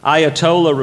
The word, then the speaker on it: Ayatollah: Ayatollah refers to a spiritual master or leader in Shiite Islam.